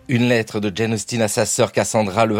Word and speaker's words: Jane Austen: Jane Austen comme 10, 10 en anglais, voilà, c'est la prononciation anglaise. Mais Jane Austen bénéficie d'une prononciation française. Autrice de la fin du 18e siècle, du début du 19e siècle, c'est un peu comme le roi du Royaume-Uni, Guillaume IV au 19e siècle. Personne en France ne l'appelle William the Fourth. C'est une prononciation française, alors Jane Austen ou Jane Austen, passion Jane.